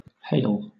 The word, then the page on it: hale: British English